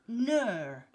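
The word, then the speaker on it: gnurr: It's a great word to say, but I'm not quite sure how to pronounce it. It's gnur. It's G-N-U-R-R.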